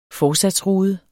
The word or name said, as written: forsatsrude